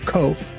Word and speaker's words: Kvothe: His book series, The Name of the Wind, The King Killer Chronicles, the main character of the book series, Kvothe, it's K-V-O-T-H-E.